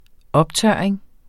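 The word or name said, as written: OPTØRRING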